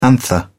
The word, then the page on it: anther: British English